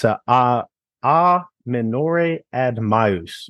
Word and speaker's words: a minore ad maius: In Latin, it's a minore ad maius, right?